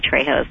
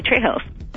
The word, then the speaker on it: Trejos: I'm also on Twitter as Nancy Trejos, and I have a Facebook page. It's Nancy Trejos. – And we should add that Trejos is spelled T-R-E-J-O-S.